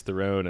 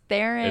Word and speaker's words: Theron: Charlize Theron as Cypher. – Theron. It's pronounced Theron. – It's Theron. My bad.